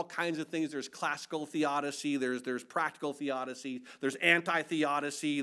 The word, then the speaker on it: theodicy: Well, there's a term in Christianity called theodicy. T-H-E-O-D-I-C-Y, theodicy. Some people say it's theodicy, but it's not. It's theodicy is how it's pronounced.